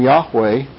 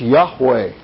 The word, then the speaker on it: Yahweh: So they called him Jehovah, which is a combination of the vowels from Adonai for Lord and the consonants from Yahweh. Put them together, you have Jehovah. But literally, his name is pronounced Yahweh. There is no Jehovah. Okay? Yes. What's Yahweh? Y-A-H-W-E-H. Yahweh. Y-A-H-W-E-H.